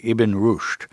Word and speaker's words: Ibn Rushd: The story actually begins, eight centuries ago with a 12th century philosopher named, I hope I pronounced this correctly, Ibn Rushd.